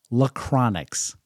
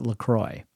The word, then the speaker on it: LaCroix: By spelling, and it's spelled capital L-A, capital C-R-O-I-X. So, I don't know how you say this, but my daughter Grace loved to pronounce it Lachronix. And in our family, when I read C-R-O-I-X, and it's prefaced by La, I would pronounce it LaCroix. So I do believe that is the correct pronunciation, and I've heard it pronounced by many, many other people that way.